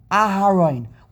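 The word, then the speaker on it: Aharon (אהרן): Aleph, He together has the sound of a He, Aharon. When you say Aharon, you have to have that He sound.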